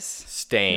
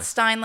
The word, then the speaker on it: Berenstain: It's Berenstain Bears. – Stain. – Not Stein like Frankenstein. – S-T-A-I-N? – Yeah.